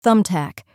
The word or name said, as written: thumbtack